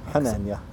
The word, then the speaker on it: Hanania: By the way, is it pronounced Hanania? – Hanania. – Hanania. – Yeah.